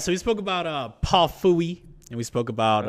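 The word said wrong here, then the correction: Phua: That's not how you pronounce his name. – How you pronounce... Sorry, Paul. – Paul Fua. All right.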